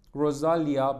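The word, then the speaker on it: Rosalía: روزالینیا روزالیا ببخشید روزالیا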